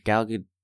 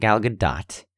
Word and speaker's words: Gal Gadot: Like, I've heard Gal Gadot. By the way, we've been talking about Gal Gadot for 3 years. Nobody can agree on how her name is said. It's not Gal Gadot.